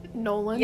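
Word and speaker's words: Nolen: Nolan.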